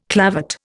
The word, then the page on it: clavate: Indian